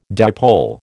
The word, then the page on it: dipole: American English